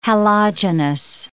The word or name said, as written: halogenous